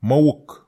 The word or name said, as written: mawuk